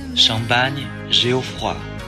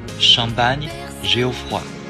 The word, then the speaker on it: Champagne Geoffroy: 今天教大家一下法语单词的发音：Champagne Geoffroy、 Champagne Geoffroy、 Champagne Geoffroy。